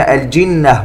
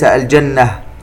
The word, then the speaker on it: الجنة: الجنة، النون المشددة تغن بمقدار حركتين، وننتبه إلى أن الكلمة الجنة وليس الجنة.